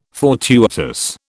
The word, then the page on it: fortuitous: British English